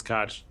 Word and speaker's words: Koch: Yeah, it was Miller Diaz and Matt, I think he pronounces it Cook, K-O-C-E-H. – Yes, yeah. – Or if it's Koch, I'm sorry, Matt.